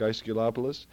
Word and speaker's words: Aesculapius: It's Aesculapius, Aesculapius, Aesculapius. It's actually Aesculapius.